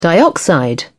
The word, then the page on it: dioxide: British English